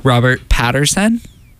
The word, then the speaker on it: Robert Pattinson: Robert Pattinson? – Well, so good. – That's one way to say his name.